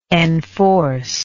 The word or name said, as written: enforcive